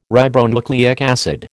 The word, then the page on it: ribonucleic acid: American English